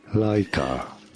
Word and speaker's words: Laika: Laika. – Oh, excellent. And you guys can't hear it either. Oh, this is good for you guys, but the listeners heard it. It sounded like Laika.